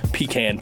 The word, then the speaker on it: pecan: So the way we pronounce everything is pretty much right, except you're wrong on the pecan. – Oh, okay. – Pecan is the correct way to say it.